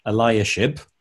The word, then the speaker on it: Eliashib: And now, Eliashib, is that how we say it? You say it better than me. – Okay. I've been pronouncing it Eliashib.